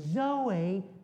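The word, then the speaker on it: Zoe (ζωή): Z-O-E with a housetop over the E. It's a long E.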